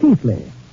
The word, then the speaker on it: Keighley: The Brontes lived in Yorkshire, England, in the 19th century, not far from my ancestral town of Keighley, well, pronounced in England, Keighley. Although how they made Keighley out of K-E-I-G-H-L-E-Y, I'll never know.